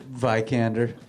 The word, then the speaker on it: Vikander: How do you think it's pronounced? – Vikander. – Okay, there you go. That's what I said.